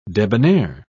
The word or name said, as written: debonair